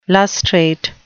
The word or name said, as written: lustrate